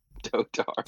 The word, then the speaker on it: dotard: Emphasis on the first syllable is the important part, probably. – Yeah, and yeah, dotard. – It's not dotard. Why is everyone saying dotard? – Nice.